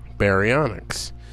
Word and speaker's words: Baryonyx: Be- Bary, Barry, B A R Y. And Onyx, like you'd think it. It's, it's spelled the way you think it would be Barry Onyx.